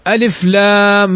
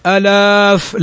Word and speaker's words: ألف: وهناك حرف لا يمد أصلا إنما ينطق به هكذا وهو الألف، فنقول ألف لام، ما نقول ألاف، لا، ألف لام. لماذا لا نمده؟ لأنه ألف ليس أوسطه حرف مد، أوسطه لام، ألف لام.